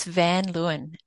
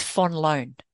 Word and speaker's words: van Luyn: So it's a Dutch surname, actually. So not far from where you are. It's Van Luyn. If you pronounce it like a Dutch person, though, as my dad would, because he hates the, you know, English pronunciation, it's Van Loon.